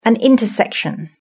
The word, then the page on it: an intersection: British English